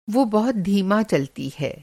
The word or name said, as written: धीमा